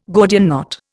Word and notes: Gordian knot: Indian

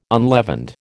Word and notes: unleavened: American English

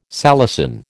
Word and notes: salicin: American English

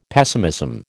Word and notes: pessimism: American English

